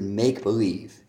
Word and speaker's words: make-believe: Make-believe also, usually I say a stress on the make part of make-believe. With make-believe, make-believe.